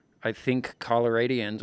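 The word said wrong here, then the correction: Coloradan: or how do you say that? – Coloradans, Coloradans.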